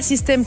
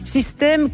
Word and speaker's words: Systaime: Mais pas System comme un système, System comme je t'aime. Enfin, ça s'écrit System, S-Y-S-T-A-I-M-E.